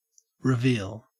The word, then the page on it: reveal: Australian English